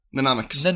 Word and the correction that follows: mnemonic: Mnemonic, sorry, I've been saying it wrong. Mnemonic.